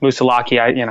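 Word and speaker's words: Moosilauke: I can, you know, the day that I hiked over Moosalak, or it's pronounced Moosalaki, Mount Moosalak, which is the last mountain in the White Mountains before I, successfully got through those.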